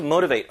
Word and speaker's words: motivate: 이 T 발음이 완전히 리을처럼 북미권 발음에서 바뀝니다. 영국 발음에서는 안 그래요. 근데 미국 발음 기준으로 하면 이 T가 리을로 바뀌면서 motivate 이런 느낌이 납니다.